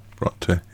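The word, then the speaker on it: Brontë: War wieder erwarten kein Horrorfilm, wie manche gedacht haben, sondern geht um Emily Bronte oder ja, Bronte würde ich glaube ich ausgesprochen. – Bronte, ja.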